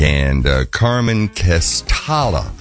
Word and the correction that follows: Castaldi: Castaldi is on drums.